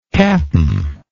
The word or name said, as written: capn